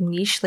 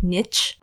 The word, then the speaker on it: niche: It's wrong nonetheless, because it should be niche, because it comes from the French.